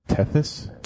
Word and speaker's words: Tethys: T-H-Y-S. I couldn't say it right, I think. – T-E-T-H? – T-E-T-H-Y-S. – Tethys? – Tethys. – That's the sound.